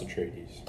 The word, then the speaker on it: Atreides: Yeah, Duke Leto of the House Atreides.